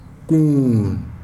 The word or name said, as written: kuun